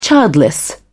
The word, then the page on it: childless: British English